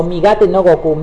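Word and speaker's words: Migatte no Gokui: Se trata de una técnica que se llama Migate no Goku, Ultra Instinto, Migate, migate o migate no Goku, migate o migate, ¿cómo se dice? Migate. Migate no Goku. Eh, migate no Goku, mejor dicho.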